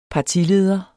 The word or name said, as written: partileder